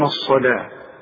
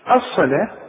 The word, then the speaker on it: الصلاة: ويقيمون الصلاة. الصلاة مكتوبة بالواو لكن تنطق بالالف، شوف الصلاة، واخد بالك.